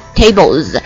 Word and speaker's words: tables: 还有下面这个 table 它最后一个音标，你看呢它是以了浊辅音了结尾的，所以它的发音呢也是 z 呢 tables。